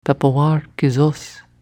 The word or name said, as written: Pepewarr kesos